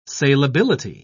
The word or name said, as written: salability